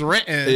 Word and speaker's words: Renton: The correct way to say Renton is Renton.